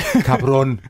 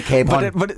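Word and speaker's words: cabrón: Nu har jeg sagt det til dig 10 gange. Cabron. Ikke Capron.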